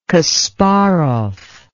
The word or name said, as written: kasparov